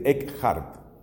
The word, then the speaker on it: Eckhart: Eso se escribe con la letra K y después viene la H, como decir Eckhart. Acaba en la letra T.